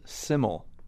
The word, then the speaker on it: SYML: It's spelled S-Y-M-L. So let's start there. – It's called SMYL, and most people think it's an acronym, but it's really a word in Welsh that means simple.